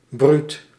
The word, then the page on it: fierce: American English; [fɪə(r)s]; RP; /fɪəs/; General American; /fɪɹs/